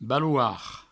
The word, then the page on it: baloard: balouard; Provençal